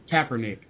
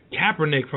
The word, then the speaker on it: Kaepernick: How do you say his last name? Kaepernick. – Kaepernick from Nevada.